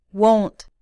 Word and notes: won't: American English

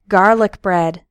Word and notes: garlic bread: American English

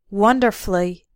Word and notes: wonderfully: American English

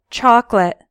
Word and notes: chocolate: American English

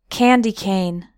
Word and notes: candy cane: American English

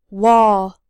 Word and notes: wall: American English

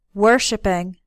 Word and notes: worshiping: American English

